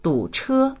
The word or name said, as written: du3che1